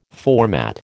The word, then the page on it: format: American English